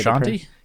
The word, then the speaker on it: Frusciante: Frusciante? – Yes, John Frusciante.